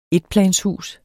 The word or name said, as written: etplanshus